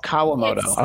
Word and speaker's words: Kawamoto: Oh, Kawamoto.